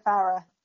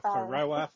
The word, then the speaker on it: Farah: Oh, actually, as you said Sophia, how do you pronounce her surname quickly? – Sophia Farah. – Okay, because I keep on getting it wrong. – I've probably now said it wrong, but no, I think that is right. – Okay, we've had discussions Farah. About it before. I'm like, Faroa, Faroa. – I would always say Farah, and she's never corrected me, so let's go with that.